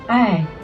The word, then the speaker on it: Ei: Ei.